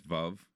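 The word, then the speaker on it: Veuve: Google says "verve." I think Lorenzo and I said vuv, vuv. – Okay. – So, but it doesn't really matter how you pronounce it. It's vuv, as in rhymes with love.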